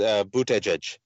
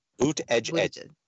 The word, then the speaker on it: Buttigieg: And then there's the pronunciation for Pete is Buttigieg. Buttigieg.